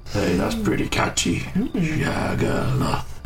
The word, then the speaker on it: Shaggaloth: I've only just realised that the name you is Shagaloff. I put that into a random orc name as well, and that it's spelled S H A G G A L O T H.